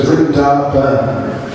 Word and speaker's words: Vrindavan: There's all these vans, or they usually pronounce it Ban. So Vrindavan.